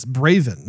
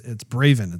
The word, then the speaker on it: Braven: You know, I may have mispronounced this this title, but it's Braven, not Brazen. Did I say Brazen early on? – I probably did. – If I did, I apologize. It's Braven. It's a V.